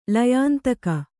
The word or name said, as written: ಲಯಾಂತಕ